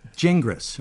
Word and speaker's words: Gingras: My good friend Tim Gingras. – Tim Gingra. – Gingra, is that it? – Yeah. – Okay. – That's the French pronunciation.